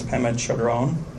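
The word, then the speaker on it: Pema Chödrön: I believe it's Pema Chodron. – You got it right.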